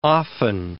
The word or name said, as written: often